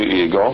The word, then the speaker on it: Eagle: And the name is spelled just like the great American bird, E-A-G-L-E.